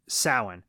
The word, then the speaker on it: Samhain: The second is that he scrawled Samhain on the wall, which Dr. fucking Loomis pronounces as Samhain. That is not how you say the word, okay? It's Samhain.